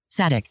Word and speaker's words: Sadik: Let me see if I'm saying his name right, man. – Sadik. – Yeah, I did say it right.